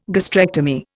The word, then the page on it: gastrectomy: Indian